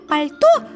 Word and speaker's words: пальто: Фома, он написал, что в слове «пальто» звуков больше, чем букв. А там ведь мягкий знак, он не произносится.